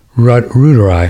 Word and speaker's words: reuteri: Oh, I just heard from Living Streams, there is no Lactobacillus reuteri. How do you pronounce that? Reuteri.